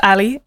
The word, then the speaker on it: Ally: It's my Spotify is just Ali, A-L-L-Y.